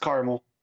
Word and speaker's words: caramel: We don't respect the second A. It's just caramel.